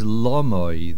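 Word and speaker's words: lomoi (למו): The word is lomoi. The main accent is on the lamed. Lomoi.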